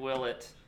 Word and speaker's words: Willett: I'm glad you learned to say his name right after 4 years. You said Danny Willett.